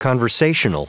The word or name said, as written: conversational